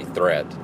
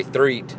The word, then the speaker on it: Threet: I think Trey Threet will – I wish you said his name Trey Threat, because that's how it's spelled. But Trey Threet, I think he'll be good.